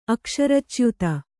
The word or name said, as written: ಅಕ್ಷರಚ್ಯುತ